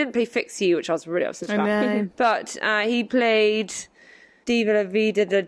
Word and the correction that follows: Viva la vida: diva, de, I always say it wrong. – Viva la vida.